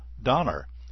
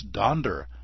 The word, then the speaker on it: Donder: Why do I obsess over the fact that Gene Autry mispronounces Santa's reindeer's name as Donner? It's Donder, I tell you.